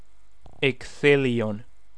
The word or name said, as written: Ecthelion